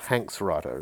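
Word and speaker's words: Hanx Writer: One of them is called Hanks Writer. It's endorsed by Tom Hanks, but it's spelled H-A-N-X Writer.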